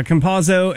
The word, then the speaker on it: Campazzo: Campazzo, is that how you say his name? – Yes. – Yeah, Campazzo, and then someone else.